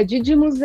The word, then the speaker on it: Didymus: A, o asteroide ao qual a gente se refere aqui é, me corrijam se eu estiver errado, é o Didymus É assim que pronuncia? – É, Didymus é o maior, né? – Uhum.